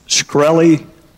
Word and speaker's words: Shkreli: Is it pronounced Shkreli? – Yes, sir.